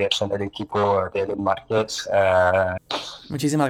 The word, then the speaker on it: LN Markets: bueno, este, soy, eh, soy el Cypher Community Manager de Ellen Markets. Esa sería la manera en que se pronuncia.